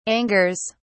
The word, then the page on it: angers: British English